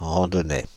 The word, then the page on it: randonnais: Paris; [ʁɑ̃.dɔ.nɛ]